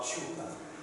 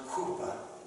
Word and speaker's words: chuppah: It looks like chupa, but it's pronounced chuppah.